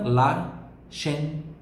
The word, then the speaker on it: ascensore: L'ascensore. L'ascensore. Porque o SCE tem som de SH.